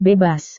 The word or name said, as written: bebas